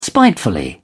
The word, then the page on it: spiteful: American English